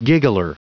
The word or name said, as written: giggler